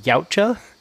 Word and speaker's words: Yautja: They, like, add a bunch of, like, lore to the Predator, like, species, which is called, I believe it's pronounced Yautja. It's Y-O-U-T-J-A. – Yeah, it is. – Yeah, I don't know. – Yotia.